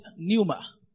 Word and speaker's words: pneuma: The word spirit as used here is the word pneuma. You may have to write and catch along. The word spirit as used here is the Greek word pneuma. Pneuma is spelled P-N-E-U-M-A. P-N-E-U-M-A.